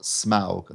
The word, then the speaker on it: Smaug: Well, Mark, this week it was The Hobbit, The Desolation of Smaug. That's Smaug, not Smaug. Apparently I've been saying it wrong all these years.